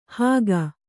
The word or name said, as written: ಹಾಗ